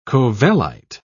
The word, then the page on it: covellite: British English